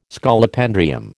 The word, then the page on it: Scolopendrium: American English